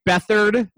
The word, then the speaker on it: Beathard: I, I don't think we're expecting CJ Beathard, Bethard, Bethard, Bethard, whatever. – Bethard. – It's actually it's actually Bethard. If anybody really wants to know, and yes, it's, that's actually how it's spelled.